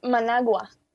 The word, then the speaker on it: Managua: What is the correct way to pronounce Managua, Nicaragua? – It's Managua.